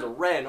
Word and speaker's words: Ren: So yeah, my name is Ren, R-E-N.